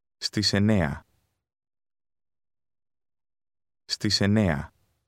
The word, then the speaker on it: στις: Στις. Στις. Στις εννέα. – Notice the S sound added to the Greek word at.